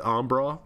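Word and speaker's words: Embarras: Or, like, you know how people, you know, like in Kentucky, there's the Ombra River, which is spelled exactly like embarrass. But the people pronounce it Ombra.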